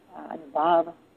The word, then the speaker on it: anubhav: And if you look up the use of the word anubhav, anubhav with a long a, you'll find that it's described very differently in different places.